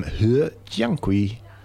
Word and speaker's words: He Jiankui: He Jiankui. I'm told that that's the correct pronunciation, or close anyway.